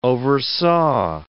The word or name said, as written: oversaw